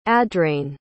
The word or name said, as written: adrain